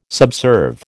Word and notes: subserve: American English

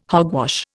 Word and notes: hogwash: Indian

